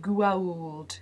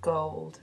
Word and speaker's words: gold: Your pronunciation was much better this time, but do be careful because it sounded like you were singing to those aliens from Stargate when you said Gwauld instead of gold.